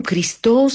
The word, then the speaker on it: Кристо: Маргарита, аз казвам Кристо с ударение, може би, на последната сричка заради френското произнасяне на името му.